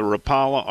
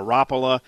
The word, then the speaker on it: Rapala: I didn't realize so many people were on to the Rapala or Rapala. People pronounce the name different ways, but I got a lot of people, and it's their go-to knife.